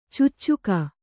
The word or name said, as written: ಚೂಚುಕ